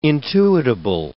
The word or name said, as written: intuitable